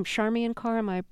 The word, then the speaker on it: Charmian Carr: The female singer we'll hear, is her name Charmian Carr? – That's correct. – Pronouncing that correctly? – That's correct. – Okay.